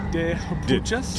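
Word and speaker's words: De Juepuchas: De Hupuchas? – D-E. And then the next word is J-U-E-P-U-C-H-A-S.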